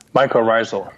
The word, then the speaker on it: mycorrhizal: Adding mycorrhizal. How do we say that? – Mycorrhizal. – Mycorrhizal. Thank you.